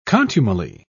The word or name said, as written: contumely